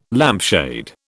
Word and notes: lampshade: British English

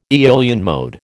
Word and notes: Aeolian mode: American English

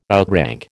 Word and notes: outrank: American English

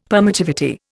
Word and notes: permittivity: Indian

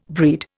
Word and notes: breed: Indian

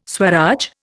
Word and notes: Swaraj: Indian

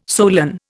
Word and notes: solan: Indian

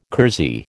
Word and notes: kersey: American English

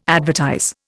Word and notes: advertise: Indian

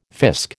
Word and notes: fisc: American English

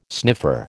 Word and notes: sniffer: American English

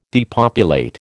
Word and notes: depopulate: American English

